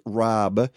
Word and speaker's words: rabe: And by the way, folks, broccoli Rob is spelled R-A-B-E, pronounced Rob, yeah.